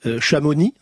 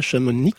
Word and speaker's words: Chamonix: Chamonix. C'est pas Chamonix, c'est Chamonix.